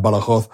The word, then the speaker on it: Badajoz: spelt Badajoz, but it's pronounced Badajoz.